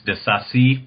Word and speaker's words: de Sacy: Am I saying his name right? – I believe it's de Sacy, but I'm no French speaker, so I could be wrong too.